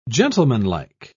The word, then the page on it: gentlemanlike: British English